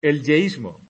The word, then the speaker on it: yeísmo: El yesismo. Perdón, el yeísmo. Es que no sé por qué he puesto yesismo.